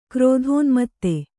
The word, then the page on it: ಕ್ರೋಧೋನ್ಮತ್ತ: krōdhōnmatta